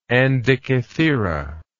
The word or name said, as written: Andikithira